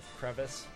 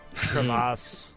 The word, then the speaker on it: crevasse: You like my fancy pronunciation of crevice? Crevasse.